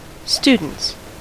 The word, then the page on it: students: American English; /sty.dənts/